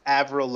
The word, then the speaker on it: Avril: Is it Avril? – It's Avril Lavigne. Yeah. – Avril. So